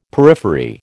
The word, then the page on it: periphery: American English